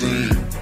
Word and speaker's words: zine: It's pronounced zine. Zine, zine, zine.